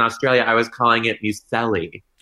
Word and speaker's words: muesli: We call it muesli.